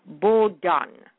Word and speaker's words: Bowdoin: So there he had a fellow while he was sent to, it's spelled B-O-W-D-O-I-N, but I looked it up online and it's pronounced, it's a French name, so it's pronounced Bodon, B-O-W-D-O-N.